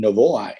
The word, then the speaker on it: Novoa: My name is spelled B-R-Y-N, that's Brian, and Novoa, N-O-V-O-A.